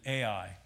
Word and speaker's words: Ai: But when the inhabitants of Gibeon heard what Joshua had done to Jericho and Ai, not artificial intelligence. Really, we should pronounce that A-I, if it would be pronounced in Hebrew.